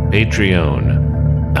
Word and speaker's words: Patreon: We've even got one of those Patron things. Patreon. I always pronounce it like the tequila.